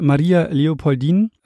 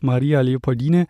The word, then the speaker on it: Maria Leopoldine: Wir beginnen mal mit Maria Leopoldin. Wie gesagt, ich habe beide ähm, Ausspracheweisen gefunden, auch Maria Leopoldine. Ich werde jetzt aber einfach mal bei Maria Leopoldin bleiben.